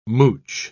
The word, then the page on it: mouch: British English